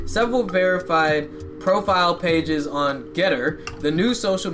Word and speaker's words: GETTR: It is spelled G E T T R. Now pronounce it. G E T T R. Jeter? Make that G the hard G, not the soft G.